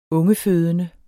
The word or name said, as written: ungefødende